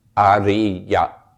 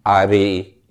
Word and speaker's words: ariya: Arya. Arya. A-R-I-Y-A, Arya. And I kind of speak a hybrid Thai-American pronunciation of Pali, so don't assume I'm pronouncing it right.